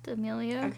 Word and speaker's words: D'Amelio: How do you pronounce her last name? – D'Amelio. – Okay.